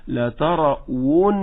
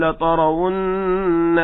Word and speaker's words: لترون: واو كسر وي، واو ضم وو، فصعب عليه، لا بس هي مش صعبة، هو مش منتبه سماعا كده، سمع القاري يقول لترون فظنها هاء، فبداية قبل أي شيء انتبه أيها المستمع الكريم، هذه واو مضمومة، فنقول لترو، ليست هاء، واو مضمومة، لترون، لترون هكذا.